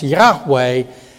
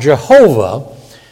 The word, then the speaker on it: Yahweh: Well, any Jew or Gentile Hebrew scholar will say, no, it's pronounced Yahweh, not Jehovah.